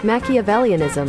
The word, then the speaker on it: Machiavellianism: Machiavellianism.